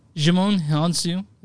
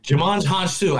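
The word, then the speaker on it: Djimon Hounsou: Okay, so Jimon Hounsou is in this movie. – Jimon Hounsou.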